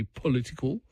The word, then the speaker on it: political: He says, Tom, you pronounce the word political like Pauline Hanson, as in you forget the O. Wasn't aware of that. So it should be political.